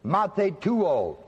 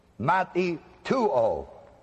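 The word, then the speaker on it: matheteuo: A second word that I want you to note tonight is the word Mathetuo. It's spelled on your screen, M-A-T-H-E, and that's a long E. Put a line over the top of it, T-E-O, long O at the end, Mathetuo.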